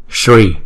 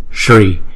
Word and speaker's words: Sri: Chẳng hạn như chữ Sri này nè, chúng ta phiên âm là Sri nhưng chúng ta không đọc là Sri. Nếu mà chúng ta theo tiếng Việt đọc là Sri thì nó rất là, nó là thành hai âm. Nhưng mà chữ này thật là chữ S nó kèm với chữ Ri là Sri, Sri.